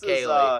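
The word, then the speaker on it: Kayli: Sorry, it's pronounced K-A-Y-L-I. I knew I was going to get that wrong. – Hey-o. – You know like Haley Mills?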